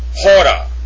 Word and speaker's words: hora: Number 16, pronounced hora, hora, and it is written in the Koine omega, rho, alpha, with a rough breathing mark over the omega.